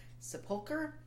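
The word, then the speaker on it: sepulchre: I just remember that it had a word that I couldn't pronounce at the time, which I now think is pronounced... Sepulcher. – Yeah.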